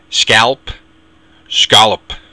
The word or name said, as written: scealp